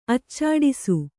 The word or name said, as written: ಅಚ್ಚಾಡಿಸು